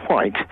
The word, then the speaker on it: White: You do speak correctly, because my name is White. – Yes. – That's my surname, and yeah. – I always pronounce my H.